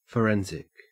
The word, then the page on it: forensic: Australian English